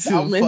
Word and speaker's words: salmon: Salmon. It's salmon. A fucking metaverse salmon. Blackboard. It says the L is silent.